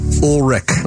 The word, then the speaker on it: Ulrich: Ulrich.